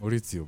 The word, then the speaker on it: Maurizio: Maurizio, man. – Maurizio Sarri.